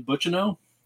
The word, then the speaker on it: Buccino: It's actually Buccino. – Buccino. Okay. – Believe it or not, the last name is French.